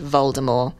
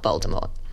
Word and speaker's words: Voldemort: And they also say Voldemort, because that's something JK said now, that it's pronounced Voldemort and not Voldemort. – What's the difference? – Voldemort with no T.